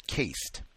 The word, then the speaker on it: caste: Two, hey, Gus, I wanted to bring your attention to the pronunciation of CASTE. It is pronounced the same as CAST, like you would have on a broken arm.